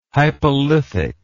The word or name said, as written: hypolithic